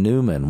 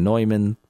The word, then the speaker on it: Neumann: At the shrine of St. John Newman, one of the first archbishops or bishops of Philadelphia, St. John Newman, St. John Neumann, pronounced different ways.